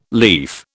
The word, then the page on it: leaf: British English